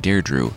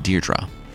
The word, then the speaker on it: Deirdre: There are some characters that won't make another appearance, like the woman I was calling Deirdre. Remember, she died in the chariot. Her name is actually pronounced Deirdre.